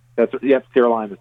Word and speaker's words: sirolimus: And that was, if I'm pronouncing this correctly, tell me, sirolimus? – Sirolimus. That's right, yep.